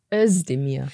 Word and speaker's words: Özdemir: Ich dachte aber, es heißt Özdemir. Mit summenden S.